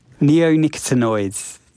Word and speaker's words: neonicotinoids: Neonicotinoids, or neonics for short.